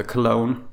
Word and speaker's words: Cologne: Jag åkte till en stad som heter Cologne. Ja, det uttalas exakt som uh, cologne.